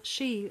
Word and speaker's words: Sidhe: Okay, well, She is spelled S-I-D-H-E, means a people of the hills. It's the Gaelic name for fairies. and descended from people defeated in war, the Xi retreated to a different dimension of space and time.